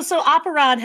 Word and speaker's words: Operon: So Operon has the same Latin root as opera and operator, so however you pronounce those words, it's just opera and then on.